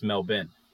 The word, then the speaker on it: Melbourne: But it's pronounced Melbourne. – But it's pronounced Melbourne. They call it Melbourne over there. The locals.